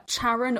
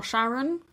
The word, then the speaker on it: Charon: The largest one, which is called Charon or Charon. It's spelled C-H-A-R-O-N.